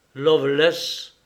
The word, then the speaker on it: Lovelace: Ce nom, qui ne se prononce « Loveless », appartient à de vieilles familles anglaises.